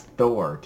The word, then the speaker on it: Thor: even though it's T-H-O-M, but it gives the pronunciation for Rand Al Thor as Thor, T-H-O-R.